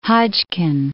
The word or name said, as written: Hodgkin